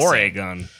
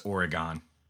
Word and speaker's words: Oregon: Dude, you can always trust the rappers to pronounce our state correctly. I gotta Oregon. – Say. You know, it like if Taylor Swift probably says Oregon.